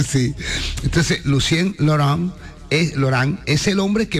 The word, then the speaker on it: Lucien Laurent: El señor Lucien Laurent. – ¡Mierda! Good pronunciation. – Le digo a los oyentes porque Sandy fue la que me dijo cómo tenía que decirlo. – Lucien Laurent.